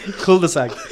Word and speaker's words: cul-de-sac: Private cul-de-sac, cul-de-sac. – Cool. It's like a lighter E. You're putting too much emphasis on the E.